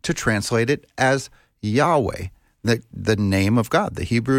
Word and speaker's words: Yahweh: And so in the Latin, when it was translated into Latin, some thought that it would be pronounced Jehovah, because Latin, I think, didn't have a Y. It had a J in its place. And so they came up with Jehovah instead of Yahweh. But the name is, I believe, that YHWH should be pronounced Yahweh.